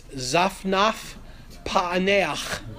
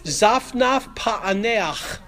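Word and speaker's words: Zaphnath-Paaneah: For the monumentally unpronounceable, it's Zaphnath Paaneach. You get points off if you don't do the chet at the end. Zaphnath Paaneach.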